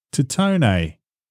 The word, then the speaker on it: Titone: Adam Sandler weds actress model Jackie – Tatone. – Is that how you say it? Good, thank you. – Otherwise, it's tit one. And after talking about Stripperella, I think we'll just go with Titone.